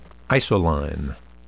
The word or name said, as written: isoline